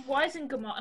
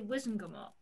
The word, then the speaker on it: Wizengamot: You say Weisengamot, and I would say Wizengamot.